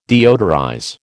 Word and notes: deodorize: American English

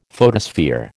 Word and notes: photosphere: American English

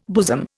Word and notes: bosom: Indian

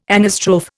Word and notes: anastrophe: Indian

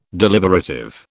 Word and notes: deliberative: British English

